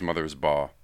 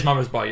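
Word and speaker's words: Mothersbaugh: I think it's Mother's Ba. – Is it? Have I been mispronouncing his name this entire time? Because I've been talking about him as a good composer. My big hot take... Yeah, it is Mother's Ba, you're right.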